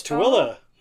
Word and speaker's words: Tooele: Oh, how do you pronounce this city? T-O-O-E-L-E. – Tuile? – Close, Tuile. Oh, congratulations.